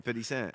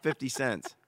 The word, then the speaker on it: 50 Cent: Name, 50 Cent. That's how you say it. You need to know how to say it. It's not 50 cents. 50 Cent.